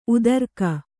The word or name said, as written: ಉತ್ತರಿಗೆ